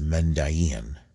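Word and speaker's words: Mandaean: I believe that the names is pronounced as Mendayin, and supposedly they're one of the few or the only remaining Gnostic sect.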